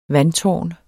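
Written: VANDTÅRNET

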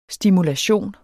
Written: stimulation

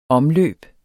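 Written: omløb

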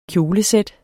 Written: kjolesæt

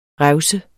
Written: revse

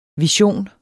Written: VISIONEN

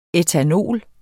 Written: ætanol

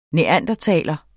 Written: homo

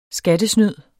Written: skattesnyd

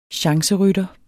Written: chancerytter

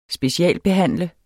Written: specialbehandle

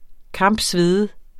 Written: kampsvede

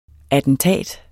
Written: attentat

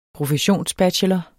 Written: professionsbachelor

